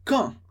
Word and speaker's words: quand: Les filles, les, as, filles, as meninas, as moças, as mulheres jovens, les filles, quand, quando, quand, atenção para a pronúncia, quand, sem pronunciar o dedo final, quand elles, quando elas, já falamos essa liaison, quand elles, quando elas são ou estão, nesse caso estão, amoureuse.